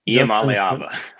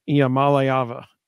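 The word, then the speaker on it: Iamaleava: So the emphasis was on the ma and the lay, according to the UT Sports pronunciation guide. So it's Iamaliava.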